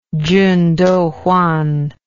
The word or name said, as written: Chun Doo Hwan